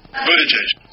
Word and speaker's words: Buttigieg: How do you pronounce the name of the South Bend mayor who's a presidential hopeful? Well, let's listen to how he says it. – Buttigieg. – So it's not Pete Buttigieg. Pete Buttigieg.